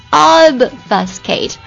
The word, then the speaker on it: obfuscate: obfuscate obfuscate obfuscate obfuscate 这个 b 音呢是可以被弱化掉的